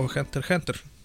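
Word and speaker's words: Hunter × Hunter: o Hunter Hunter. Porque la X no se pronuncia, cabrón. Esa X es muda.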